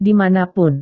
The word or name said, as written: di mana pun